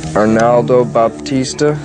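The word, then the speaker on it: Arnaldo Baptista: Arnaldo Batista, is that how you pronounce his name? – That's it.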